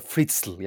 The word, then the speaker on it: Fritzl: Fritzl yani çok zor okunuyor. F R I T Z L sonunda 3 tane sessiz harf var.